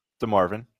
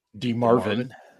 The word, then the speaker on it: DeMarvin: We got some guys that are coming along DeMarcus Leal and – DeMarvin. – DeMarvin.